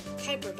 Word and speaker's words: Kuiper: Okay guys, so I just searched up how to pronounce it, and I was wrong. It's pronounced like this. – Kuiper belt. – So now you guys know how to pronounce it. Kuiper belt.